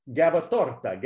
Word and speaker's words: Gavatorta: Gavatorta.